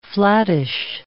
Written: flattish

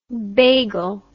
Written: beigel

